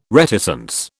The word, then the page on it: reticence: British English